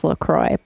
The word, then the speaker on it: LaCroix: The owners of the company says it's LaCroix. But I know that's not how the word is pronounced in French, but I just say it because it's like GIF versus JIF.